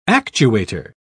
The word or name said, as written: actuator